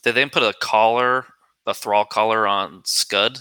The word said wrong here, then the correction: Skald: Is that how you pronounce the god's Skald. – Name? – Skald. – Skald.